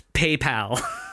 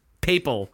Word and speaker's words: papal: Also, it is pronounced PayPal. Papal. – Papal. – Papal. P-A-P-A-L. I'm going to call it PayPal.